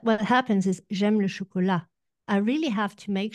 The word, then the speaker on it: chocolat: So the two words, chocolate and chocolat, sound similar, except in French, okay, we have a silent T. It is spelt with a T in the end, but it's not pronounced.